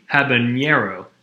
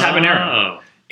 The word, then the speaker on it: habanero: That's what's called hyper pronunciation. So whenever people, like gringos, think, oh, jalapeno, it must be habanero. It's just habanero.